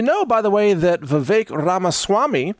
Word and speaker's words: Vivek: I was pronouncing it phonetically like Vivek. That's how it's spelled, V-I-V-E-K, but it's actually pronounced Vivek. It rhymes with cake.